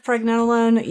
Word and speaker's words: pregnenolone: If you don't have enough pregnenolone, you don't make hormones. – Yes, it's your mother hormone. – Yes. – And I heard you say it correctly.